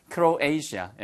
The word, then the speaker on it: Croatia: 크로아티아의 발음은 지난번에도 말씀드렸습니다. Croatia처럼 들려요. Croatia. 잘못되면 아시아야? 크로에이시아. 예, 그래서 아시아를 아시아 혹은 에이시아라고 발음하거든요.